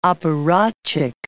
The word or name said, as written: apparatchik